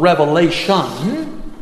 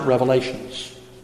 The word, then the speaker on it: Revelation: When you pronounce the book of Revelation, you should not put an S on the Revelation. You shouldn't pronounce it Revelations.